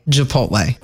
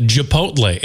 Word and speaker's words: Chipotle: And I also think you just called it Chipotle. It's Chipotle. – Regardless. That's how did you say it? – Chipotle with Chipotle. A C-H. You said Chipotle.